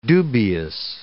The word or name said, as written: dubious